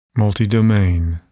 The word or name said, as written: multidomain